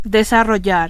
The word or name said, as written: desarrollar